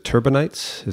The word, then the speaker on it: turbinates: And is it turbinates? Is that how you pronounce it? – Yes. Yeah,